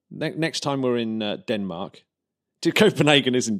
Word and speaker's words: Copenhagen: How do you say Copenhagen? I've always said Copenhagen. – I say it that. Yeah, Copenhagen.